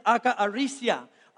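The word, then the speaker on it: akaarisia (ἀκαθαρσία): That word impurity is pronounced like this, akaarisia.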